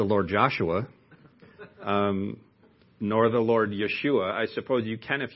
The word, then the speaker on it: Yeshua: Jesus is the English pronunciation of the Greek name Jesus, which is actually the Greek transliteration from Hebrew, the name Yeshua, which in English we, of course, pronounce Joshua. Now, aren't you glad you know that?